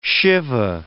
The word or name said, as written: shive